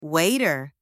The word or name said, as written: waiter